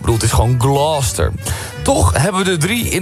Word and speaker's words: Gloucester: De Engelse plaatsnaam Gloucester spreek je uit als... – Gloucester. – Gloucester. Ja. Als één vloeiend woord. Gloucester.